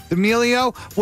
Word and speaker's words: D'Amelio: TikTok star Charli D'Amelio. Am I saying that right? – D'Amelio was also there.